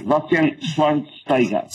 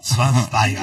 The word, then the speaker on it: Schweinsteiger: Bastian Schweinsteiger. – Schweinsteiger.